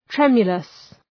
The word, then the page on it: tremulous: ‘tremjələs